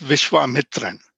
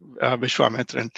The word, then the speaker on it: Vishwamitran: So his full name is Vishwamitran, which means the friend of the universe. That's what his name is supposed to be, Vishwamitran.